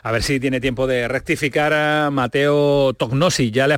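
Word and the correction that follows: Tognozzi: Con ñ, la G y la N, ñ y las dos Z como la pizza, Toñocci. Toñozzi.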